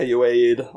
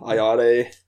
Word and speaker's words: Ayoade: Uh, I- Ayoade, Ayoade.